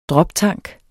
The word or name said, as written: droptank